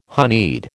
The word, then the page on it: honeyed: American English